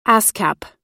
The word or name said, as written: ascap